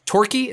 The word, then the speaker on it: Torquay: So they're a really eat digestible, something you can do while you're literally digesting your lunch, is about, I've now pronounced it. Torquay. Is that how you pronounce, Rob? – Yeah.